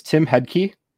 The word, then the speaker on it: Hedtcke: Yeah, my name is Tim Hedtcke.